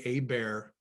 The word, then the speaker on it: Hebert: It's hard for me to say Herbert, because when I was a kid, there was an Atlanta quarterback, Bobby Hebert. – Yeah, yeah. – And he didn't say the T, right?